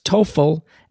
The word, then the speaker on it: TOEFL: and what a helpful listener has informed me is pronounced TOEFL as well as Praxis.